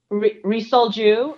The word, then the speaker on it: Ri Sol-ju: Ri Sol Ju.